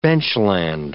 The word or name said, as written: benchland